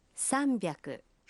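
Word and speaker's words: «Самбяку» (三百): Правильно, но с произношением может возникнуть путаница. 300 произносится… «Самбяку».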